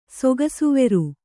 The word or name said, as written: ಸೊಗಸುವೆರು